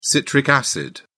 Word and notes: citric acid: British English

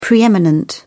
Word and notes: pre-eminent: British English

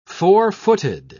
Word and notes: four-footed: British English

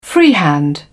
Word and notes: freehand: British English

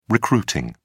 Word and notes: recruiting: British English

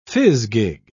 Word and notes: fizgig: British English

